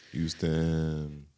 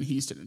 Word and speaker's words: Houston: But a lot of, like, Grambling alum end up in Houston and Dallas. – Thank you for pronouncing the H. When I was in Dallas, a lot of people said Houston.